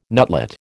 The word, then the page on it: nutlet: American English